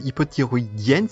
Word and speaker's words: hypothyroïdienne: parce que du coup tu es diabétique et hypothyroïdienne, si je prononce bien. – Oui, c'est ça.